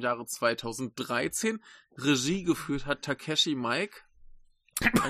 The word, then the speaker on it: Takeshi Miike: Aber Miike muss man ja nur falsch aussprechen.